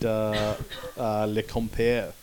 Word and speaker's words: Le Compère: It's a really great relationship, and it's one when they made Le Compère. Is that, am I pronouncing that correctly? – Yep.